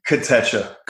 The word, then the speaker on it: Kotecha: Kotecha.